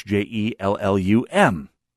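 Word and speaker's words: Hjellum: On the other hand, the other fellow, a, a lawyer in town of some repute, uh, always pronounced the name Jellum.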